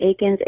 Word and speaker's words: Aikins: Um, but yeah, it's Carleigh Aikins, A I K I N S.